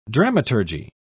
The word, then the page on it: dramaturgy: British English